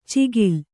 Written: ಚಿಗಿಲ್

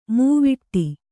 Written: ಮೈವಡೆ

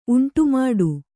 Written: ಉಂಟುಮಾಡು

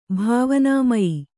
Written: ಭಾವನಾಮಯಿ

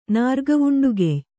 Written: ನಾರ್ಗವುಂಡುಗೆಯ್

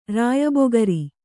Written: ರಾಯಬೊಗರಿ

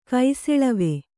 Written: ಕೈಸೆಳವೆ

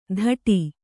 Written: ಥಡಿ

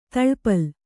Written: ಥಳಪಳ

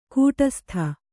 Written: ಕೂಟಸ್ಥ

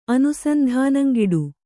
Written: ಅನುಸಂಧಾನಂಗಿಡು

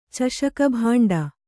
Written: ಚಷಕಭಾಂಡ

